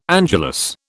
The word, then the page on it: angelus: British English